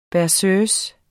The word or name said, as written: berceuse